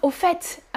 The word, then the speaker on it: au fait: Ah, au fait, um, je voulais vous parler des shorts. Oh, by the way, I wanted to talk to you about shorts. Mm-hmm. Au fait, you pronounce the T.